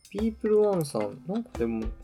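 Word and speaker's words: PEOPLE 1: ピープルワン？ – そう、名前、なんか読み方違った気がするけど、ピープルワンで合ってんのかな？ – 読み方はね、調べてピープルワンだった。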